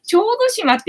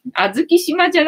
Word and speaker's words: 小豆島: 小豆島って読むのか。あずき島じゃなくて、小豆島って読むのね。あずき島だと思ってたよ。